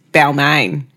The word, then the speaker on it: Balmain: And off the back of Carl's win, he was offered a job at Balmain, which is very hard to say in the French way. – Balmain. – Because I just want to say Balmain. – Because there's actually a suburb in Sydney, surroundings, Balmain. – And that's how you say it in Australia.